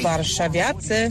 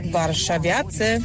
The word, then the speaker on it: Warszawiacy: You don't say Warsawians. – Warsawians. – It's Barshavyatse.